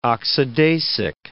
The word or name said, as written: oxidasic